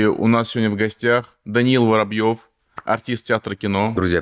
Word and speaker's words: Даниил: Так, как, как у тебя имя правильно произносится? – Даниил. Данил, Не Данил. Mm-hmm. – Даниил. – Все, я запомнил.